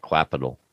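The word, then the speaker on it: Qapital: Yeah, it looks like Quapital. – You said it right.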